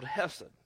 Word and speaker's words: hesed: Which is why sometimes we are required to show another type of love called hesed. It's spelled chesed. C-H-E-S-E-D.